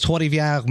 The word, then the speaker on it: Trois-Rivières: It was Trois-Rivières Metal Fest.